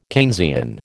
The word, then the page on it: Keynesian: American English